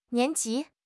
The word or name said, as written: nian2 ji2